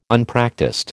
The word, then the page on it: Unpractised: American English